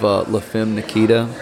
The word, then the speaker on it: La Femme Nikita: It reminded me of La Femme Nikita.